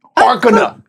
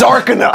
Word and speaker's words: Arcana: Arcana? Am I saying that right? – Arcana. It's Arcana.